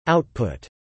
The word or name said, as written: output